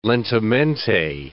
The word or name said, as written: lentamente